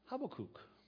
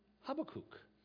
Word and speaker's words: Habakkuk: And it's because the H-A-B-A, and there is a K there, but that second K, the first K is kind of ignored in the pronunciation, but the second K there, that second one there, and then it's a long U, kook, kook. So Habakkuk.